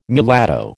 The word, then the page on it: mulatto: American English